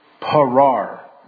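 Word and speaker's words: Parar (פרר): L-A-W-M, Olam. And it means eternity or always. The word break is Strong's number 6565. Parar, P-A-R-A-R, parar.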